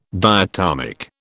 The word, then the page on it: diatomic: British English